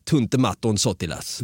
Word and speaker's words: Tuntematon Sotilas: Och du blev, du blev lite impad över att jag kunde Tuntematon Sotilas. Det blev jag, eh, för du uttalade väldigt bra.